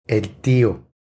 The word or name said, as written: El tio